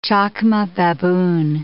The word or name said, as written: chacma baboon